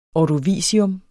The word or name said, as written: ordovicium